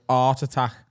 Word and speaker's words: Art Attack: So for our international listeners, there's a TV show that ran for years in the UK called Art Attack. It rhymes with Heart Attack.